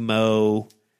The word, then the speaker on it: Meaux: How would you pronounce M-E-A-U-X? Is that Moe? – Moe.